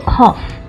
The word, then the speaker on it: cough: 然后最后呢是有一些单字的字母呢，它跟它实际的发音是不相同的，比如说咳嗽的 cough。 G H 却是发 F 的音，German 那个 G 呢，它却是发 J 的 G。